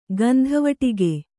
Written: ಗಂಧವಡಿಗ

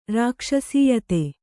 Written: ರಾಕ್ಷಸೀಯತೆ

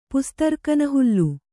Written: ಪುಸ್ತರ್ಕನಹುಲ್ಲು

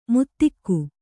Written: ಮುತ್ತಿಕ್ಕು

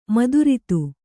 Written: ಮಧುರತೆ

